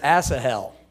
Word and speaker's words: Asahel: And it's actually pronounced Asahel.